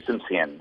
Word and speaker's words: Simsion: Graham, like Graham Crackers and Simsion.